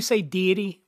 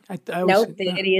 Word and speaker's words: deity: You say deity. Am I saying it wrong? Deity? – Deity is correct too. Okay. Deity. Okay. We will answer to both.